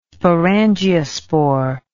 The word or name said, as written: sporangiospore